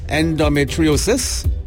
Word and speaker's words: endometriosis: So we have a question from a woman who has been diagnosed with endometriosis. Okay, I need to pronounce this good. Endometriosis. – Yes. Well done. Endometriosis. – Okay. – Endo. – Okay, wrong emphasis and wrong syllable.